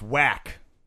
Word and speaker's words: WAC: against the WAC champion number 9, Boise State Broncos. – It's pronounced whack. – Ah, whack.